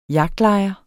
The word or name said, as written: jagtlejer